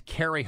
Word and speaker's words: Cary: If you want to like me on Facebook, it's Carrie Hall, and I'll spell it C-A-R-Y because I get emails asking me, how do you spell your name?